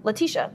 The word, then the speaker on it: Letitia: Here's the thing: if, if someone were to tell me it was Letitia, Letitia, that's how you say her name.